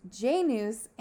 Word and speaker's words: Janus: Jaundice. – I actually mispronounced it, because in English it is Janus. But in Latin it is Janus, and he is the reason why we have the month called January.